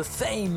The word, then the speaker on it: Faim: Allez, on va aller faire un tour en Australie avec The Fame. Hein. Donc ça s'écrit F-A-I-M pour ceux qui connaissent pas.